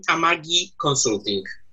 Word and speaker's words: Amagi Consulting: We normally say Amagi. That's at least what Irving Finkel, the curator of British Museum of Assyrian and Sumerian department, told me how to pronounce it.